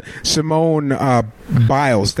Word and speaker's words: Simone Biles: Before we went on the break, I mispronounced Simon Biles' name. I think I said Simone Biles. – Oh, I just did it again. Simone Biles. That's her name.